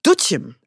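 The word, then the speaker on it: Doetinchem: Dieses Jahr ist es in, also ich hätte es ausgesprochen, Döttichem, ja, würde mich wahrscheinlich jeder irgendwie schlagen, jeder Niederländer, aber es wird ausgesprochen Döttichem.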